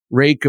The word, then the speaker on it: Ruike: Rake, of course, is the knife company from Phoenix Flashlights, spelled R-U-I-K-E, which you might think should be pronounced Ruik, but it is Rake.